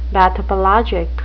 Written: bathypelagic

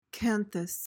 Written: canthus

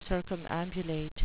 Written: circumambulate

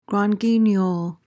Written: grand guignol

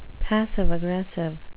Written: passive-aggressive

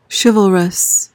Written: chivalrous

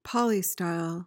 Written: polystyle

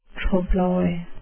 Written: trompe l'oeil

